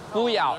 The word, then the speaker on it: Fuyao: Fuyao, is that how it's pronounced? – Fuyao. F-U-Y-A-O.